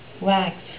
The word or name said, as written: wax